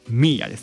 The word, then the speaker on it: mir: で、一人称の三角っていうのがミーアです。 M-I-R と書いてミーアです。 – M-I-R と書いてミーアです。